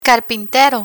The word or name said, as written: carpintero